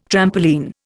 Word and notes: trampoline: Indian

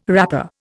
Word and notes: wrapper: Indian

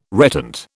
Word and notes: reptant: British English

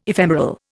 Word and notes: ephemeral: Indian

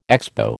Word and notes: Expo: American English

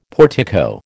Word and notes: portico: American English

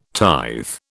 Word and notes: tithe: British English

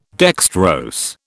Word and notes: dextrose: British English

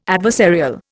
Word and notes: adversarial: Indian

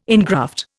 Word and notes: engraft: Indian

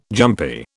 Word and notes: jumpy: British English

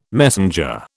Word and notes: messenger: British English

